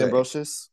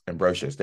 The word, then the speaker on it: Ambrosius: Marsh, I always say her last name wrong. – Marcia Ambrosius. – Ambrosius. There we go.